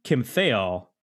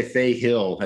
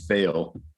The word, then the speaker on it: Thayil: And I believe it's pronounced Kim Thayil. Is that wrong? – Oh, you're correct. – Okay. There we go. See? So, and this was a lot of people try to say Thayil. It's Thayil.